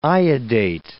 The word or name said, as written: iodate